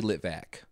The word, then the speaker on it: Litvak: We're going to start with All This and Heaven 2 by Anatoly Litvak. I probably did not say that correctly. – It's actually Litvak.